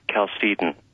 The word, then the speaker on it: Chalcedon: Is it Chalcedon or is it Chalcedon? – We pronounce it Chalcedon.